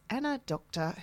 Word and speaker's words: Doktor: The first one coming up at around 4:30 is Anna Doctor, who is a wellness coach and a great name to boot. We were just saying off air. – D-O-K-T-O, the German pronunciation.